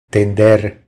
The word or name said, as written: Tender